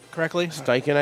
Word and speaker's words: Steichen: Shane Steichen, am I saying his name correctly? – Steichen, I think.